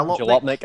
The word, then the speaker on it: Jalopnik: I've never known how to pronounce this blog, but the car blog that's related to the Gawker Media is it yeah, Jalopnik? Yeah, Jalopnik? I think I can remember.